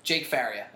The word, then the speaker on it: Jake Faria: Jake Faria, is that the correct way to say it? Right.